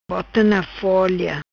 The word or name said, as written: Bota Na Folia